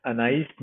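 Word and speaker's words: Anaïs: I like I like the I like the quote by um, the novelist and essayist Anas Anais Nin.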